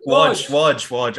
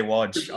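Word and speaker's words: Łódź: Yeah, so Ukraine versus Ireland in Lodz. – Wodge. – Okay, Wodge. I've got it right there, finally.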